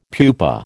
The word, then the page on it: pupa: American English